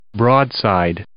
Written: broadside

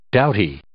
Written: doughty

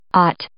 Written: aught

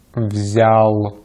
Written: взял

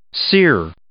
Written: sere

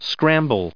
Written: scramble

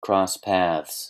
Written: cross paths

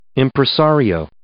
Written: impresario

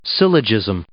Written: syllogism

